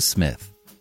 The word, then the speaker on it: Psmith: And now, leave it to Smith. The P is silent.